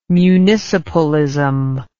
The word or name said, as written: municipalism